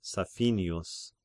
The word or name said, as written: Saphinius